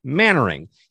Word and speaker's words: Mainwaring: Simon Mainwaring, which by the way is spelled like Main wearing M A I N W A R I N G, but it's Mannering.